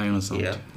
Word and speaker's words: ionosonde: So I wanted to learn more about the antenna, the ionosonde, if I'm saying it correctly. – Yes, the ionosonde.